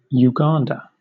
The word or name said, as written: Uganda